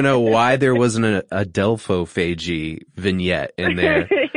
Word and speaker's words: adelphophagy: if pronouncing this wrong, so it's sand tiger sharks in particular, adelphophagy? – That's right, yeah.